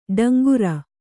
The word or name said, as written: ಟೇಂಕಾರ